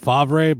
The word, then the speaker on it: Favre: it's spelled Favre, but it's pronounced Favre, weirdly enough.